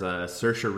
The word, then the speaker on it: Saoirse: It stars Saoirse Ronan, who's from Ireland. Shoutouts, that's how I know how to say her name.